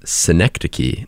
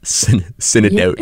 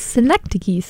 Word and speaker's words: Synecdoche: And he informed us that it is Synecdoche, New York, not Synecdoche. – Yes, it's Synecdoche. So you pronounce every like syllable. Synecdoche. – Yeah. And the CH is a K.